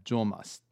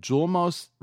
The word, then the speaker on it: Julmust: This is, It's the one word that is actually spelled like it sounds. – Yeah, Julmust. J-U-L-M-U-S-T.